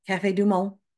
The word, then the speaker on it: Café du Monde: Café du Monde. Did I say that right? – Yes.